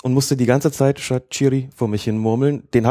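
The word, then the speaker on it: Shaqiri: Ich war so dankbar, dass ich ihn endlich richtig aussprechen kann. Rede ich mit Schweizern und was sagen die? Shakiri.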